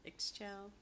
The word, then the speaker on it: Ixchel: I don't know if I'm going to say it right. Ixchel? It's I X C H E L. Ixchel? Ixchel? But. It sounds right to me.